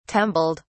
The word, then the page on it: tumbled: British English